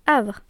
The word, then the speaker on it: AWRE: Alors, on repart sur du neuf en 2022 avec ce nom Havre. – Ça s'écrit A-W-R-E et l'acronyme se prononce Havre comme dans l'expression un havre de paix, c'est-à-dire un endroit où on est bien.